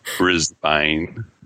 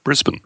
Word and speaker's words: Brisbane: Yeah, reduce the number of syllables in any word. It's not Brisbane. It's Brisbane.